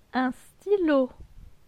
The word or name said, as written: Un stylo